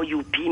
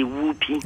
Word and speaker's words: Whoopi: Et puis je vous le dis pour pas que, parce que c'est il y a beaucoup, on dit souvent Youpi, mais c'est Whoopi.